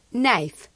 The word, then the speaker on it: knife: Knife, cuchillo. La I se pronuncia I como en baile. Observe que la K no se pronuncia cuando le sigue una N.